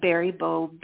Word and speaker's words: Baribault: Lynn, L-Y-N-N, and Barry Bow, B-A-R-I-B-A-U-L-T. So the A-U-L-T forms one sound, an O.